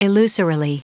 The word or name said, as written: illusorily